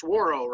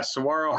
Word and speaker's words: Saguaro: Now it's pronounced Saguaro, right? – Yeah, Saguaro High School, and that's correct.